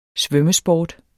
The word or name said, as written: svømmesport